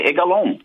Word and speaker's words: Egalon: You pronounce my name correctly, Egalon, right? It's just like your name.